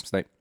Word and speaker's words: Snape: Snape? – Yes. – Snape.